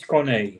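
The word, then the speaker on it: Corné: So you know, I spent my youth a little bit more with some sorrows and no, it's Corne, and many Americans and people abroad cannot find the accent on the E. So basically on many websites I just skip it because it's right.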